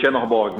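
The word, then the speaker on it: Czernobog: Uma representação disso na série, eu acho que é Kaiserborg, que, que se pronuncia, pronuncia, o nome. Que é o cara do martelo, né? Do... – Chenorborg. Uhum. – é. Chenorborg. Eu tô ótimo na pronúncia hoje, errando os nomes.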